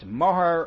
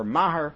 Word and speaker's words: mohar: And as I noted when we introduced the Masechta, to this day, the Muslim world has a marriage payment that is either pronounced mohar or mahar, and seems to fit this model as well.